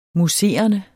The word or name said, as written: MOUSSERENDE